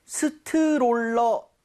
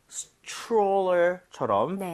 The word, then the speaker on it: stroller: 자, 근데 발음할 때 스트롤러, 아, 이건 좀 아닌 것 같아요. – 아쉽네요. – 이 모양을 좀 많이 모아서 R 사운드 제대로 돼서 약간 치읓 비슷하죠? stroller처럼 네. – 살짝 변하게. 여러분들, 이 모양 모으시고 혀 많이 당겨주세요.